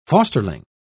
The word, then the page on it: fosterling: British English